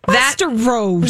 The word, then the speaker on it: Westeros: Westeros.